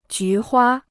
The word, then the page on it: 菊花: jú huā